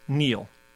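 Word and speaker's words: kneel: W and Neil. – Okay. – The K, which was silent until I spelled it earlier in the clue.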